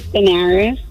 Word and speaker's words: Denerys: So, my name is Daenerys. It's spelled D-E-N-E-R-Y-S.